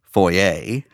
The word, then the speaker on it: foyer: And it says, through the F-O-Y-E-R. I know that word is supposed to be pronounced foyer. And I also know that in my church growing up, it was the foyer. So I knew if I said foyer, it would sound ignorant. If I said foyer, it would sound, to me, pretentious.